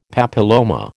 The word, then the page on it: papilloma: American English